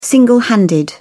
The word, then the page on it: single handed: British English